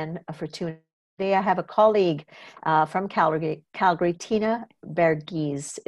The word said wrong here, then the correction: Varughese: Am I saying that properly? I know you have it explicitly written here. – You were very close. Varughese. – Varughese. Okay. Yeah, I missed an A there.